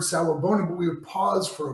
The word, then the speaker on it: Sawubona: So then he said to me, he said, you know, I'm from South Africa, and in the Zulu culture, there's a word, and it's pronounced Sawubona, Sawubona.